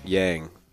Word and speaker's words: Yang: This is your host, and I'm here with SAMONG Yang. Did I say that right? – That is perfect.